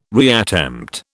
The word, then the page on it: reattempt: British English